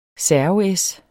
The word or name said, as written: servees